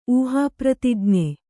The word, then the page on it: ಊಹಾಪ್ರತಿಜ್ಞೆ: ūhāpratijñe